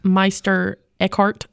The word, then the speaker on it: Meister Eckhart: Thank you, Meister Eckhart.